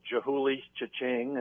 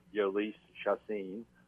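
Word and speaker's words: Jhoulys Chacín: I'm for those who don't pronounce his name well, Jahulis Cha-Ching in the opener. Of course, his name is Yolis Chacin.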